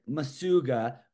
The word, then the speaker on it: Macuga: So looking at my name, it looks like Macuga, but pronounced Masuga by one of my coaches, Jay Hay, Park City. I've just kind of led into it. You see Masuga, Macuga, Cougs.